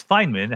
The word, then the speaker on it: Feynman: I think it was your predecessor, I read, Richard Feynman, I hope I pronounced that correctly, who said that quantum mechanics, quantum physics will never be fully understood. You know, explain what that means, and do you agree? – Yeah, it's Feynman, how he pronounced it.